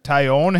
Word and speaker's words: Taillon: Taillon.